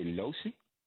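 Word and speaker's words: Locy: Are you trying to say Losey? – I'm just reading what they gave me, brother.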